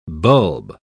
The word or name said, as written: bulb